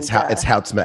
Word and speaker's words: Houtsma: It's Houtsma, actually.